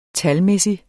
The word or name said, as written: talmæssig